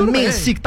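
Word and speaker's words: Menšík: O nosso querido tcheco aqui, o Jakub Mensik. Como é que fala o nome dele? – Ah, eu falei o Mensik aí, mas. – É, Mensik, tá bom.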